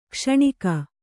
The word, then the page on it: ಕ್ಷಣಿಕ: kṣaṇika